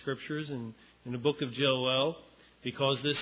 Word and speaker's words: Joel: And so with that in mind, when you read Joel, I'm using our modern pronunciation of the word. You know, you meet someone, what's your name? My name's Joel. But it's actually more correct to say Joel.